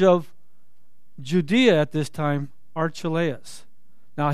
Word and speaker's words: Archelaus: And when he heard that, Archelaus, now it's funny because in the Greek it's pronounced Archahios, but you pronounce it Archelaus, I guess, was reigning over Judah instead of his father Herod.